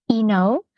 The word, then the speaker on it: iNow: So it's actually pronounced Eno. So it's also, yeah, it's a bit confusing because of the English I-N-O-W.